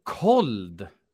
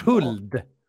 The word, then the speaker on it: Khold: K-H-O-L-D. – Alltså kold. – Men det måste väl vara... – Uttalet. – Huld.